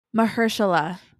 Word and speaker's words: Mahershala: It's Mahershala.